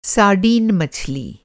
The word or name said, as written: सरडीन मछली